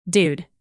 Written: dude

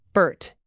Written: bert

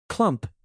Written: clump

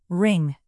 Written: wring

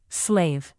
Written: slave

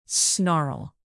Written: snarl

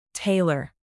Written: tailor

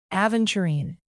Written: aventurine

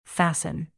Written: fasten

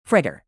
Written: fritter

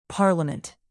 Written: parliament

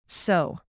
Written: sew

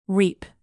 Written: reap

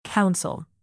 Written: counsel

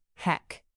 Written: heck

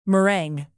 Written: meringue

